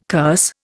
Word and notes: curse: Indian

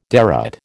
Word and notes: derate: American English

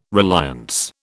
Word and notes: reliance: British English

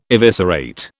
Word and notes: eviscerate: British English

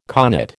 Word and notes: connate: American English

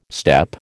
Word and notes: steppe: American English

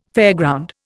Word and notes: fairground: Indian

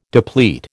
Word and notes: deplete: American English

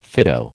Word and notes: Fido: American English

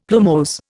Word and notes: plumose: Indian

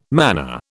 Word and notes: manner: British English

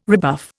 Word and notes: rebuff: Indian

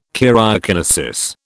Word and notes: karyokinesis: British English

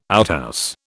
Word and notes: outhouse: British English

